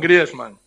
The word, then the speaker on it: Griezmann: Me gustaría que tú dijeras cómo se dice en francés Antoine Griezmann.